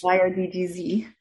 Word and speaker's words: YRDGZ: How do you pronounce this in your head? Is it YRDGZ or do you like, is it like yard? In my head, I say every letter.